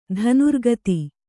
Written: ಧನುರ್ಗತಿ

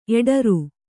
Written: ಎಡರ್

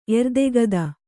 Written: ಎರ್ದೆಗದ

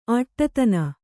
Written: ಆಟ್ಟತನ

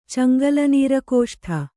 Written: ಚಂಗಲನೀರಕೋಷ್ಠ

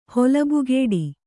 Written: ಹೊಲಬುಗೆಡು

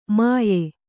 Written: ಮುಯ್ಯೀ

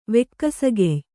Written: ವೆಕ್ಕಸಗೆಯ್

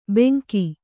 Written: ಬೆಂಕಿ

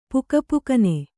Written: ಪುಕಪುಕನೆ

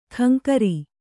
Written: ಕಂಕರಿ

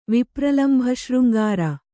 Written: ವಿಪ್ರಲಂಭಶೃಂಗಾರ

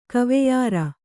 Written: ಕವೆಯರ

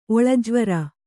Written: ಒಳಜ್ವರ

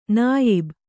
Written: ನಾಯಬ

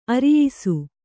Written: ಅರೆಯಿಸು

